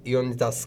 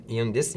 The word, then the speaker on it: Ioannidis: Bununla ilgili John Ioannidis galiba böyle okunuyor. John Ioannidis.